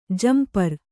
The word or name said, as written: ಝಂಪರು